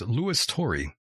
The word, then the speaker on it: Turi: Luis Turi. That's the way they would say it down south, but his name is Luis Turi. For those who were wondering, that is the proper pronunciation here.